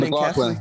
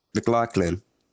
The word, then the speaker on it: McLaughlin: How do you say Bishop McLanning McLaughlin. – Catholic? – McLaughlin. Yeah.